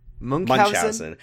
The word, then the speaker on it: Munchausen: You just YouTube pronunciation book Munchausen, and it'll tell you that it's pronounced Munchausen.